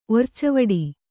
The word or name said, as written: ಒರ್ಚವಡಿ